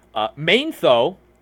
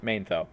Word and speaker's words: Manetho: Manetho, he wrote about Jews doing blood libel and draining the blood of Egyptian children. Okay? He was a Egyptian priest, Manetho, right? Or Manetho.